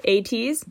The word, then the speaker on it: ATEEZ: Is that how you say it? ATEEZ? Mm-hmm.